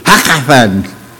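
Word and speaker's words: Hakkatan: I can pronounce it because it's Hebrew. He's like Hakathan. As if you're choking. Hakathan.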